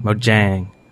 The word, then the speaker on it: Mojang: Team Mojang? Mojang. I forgot how to say it again. Mojang. – Mojang. – Mojang.